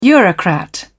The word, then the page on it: eurocrat: British English